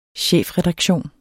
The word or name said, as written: chefredaktion